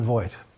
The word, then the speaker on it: Voight: Let's listen to John Bite. I pronounce his name bad. Please forgive me. – Hi, I'm John Voight.